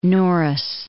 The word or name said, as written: Norris